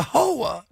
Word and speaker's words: whore: It's W-H. It's like a silent W. – You're nothing but a hoa.